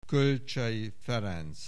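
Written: Kölcsey Ferenc

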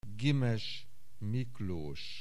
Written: Gimes, Miklós

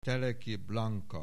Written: Teleki Blanka

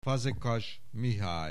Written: Fazekas, Mihály

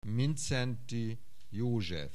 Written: Mindszenty, József

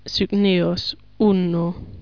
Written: Cygnaeus, Uno

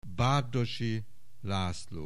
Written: Bardossy, László

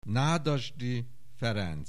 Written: Nádasdy Ferenc

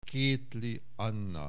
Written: Kéthly, Anna

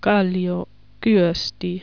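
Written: Kallio, Kyösti

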